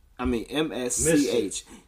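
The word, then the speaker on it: MSCHF: District Judge Eric Committee in Brooklyn ruled 3 days after Nike sued MSCH. Mischief. Yeah, Mischief Product Studio. That's not how it's spelled, though.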